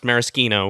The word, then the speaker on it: maraschino: that, yeah, that they're like, oh yeah, the liquor is pronounced maraschino, with the hard like CH or K sound as you would in Italian.